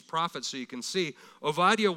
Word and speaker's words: Obadiah: we would call him Obadiah. Okay, Obadiah is actually pronounced Ovadiah.